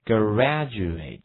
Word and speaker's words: graduate: 这个词 Graduate Graduate Graduate OK 这个 A 的发音一定要这个 A 字母，第一个字母 A 的发音一定要张开口，A 这个音要读出来哈。